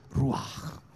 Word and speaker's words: ruach: And the Hebrew word they use is ruach, okay? And that sounds very uncultured, but that's actually the way it's pronounced, okay?